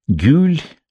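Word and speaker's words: Гюль (گل): «Гюль», — говорят в этих каменистых краях, — «и в твоих ноздрях появляется пряный запах розы».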